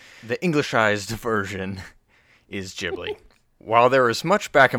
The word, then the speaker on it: Ghibli: Ghibli, with hard G, was the name of the Italian warplane that represented Hayao Miyazaki's love for planes and for the country of Italy. While the plane was pronounced with that hard G, Miyazaki changed the pronunciation, either by accident or on purpose, to fit the studio. His pronunciation is more like gibberish, with a Japanese accent.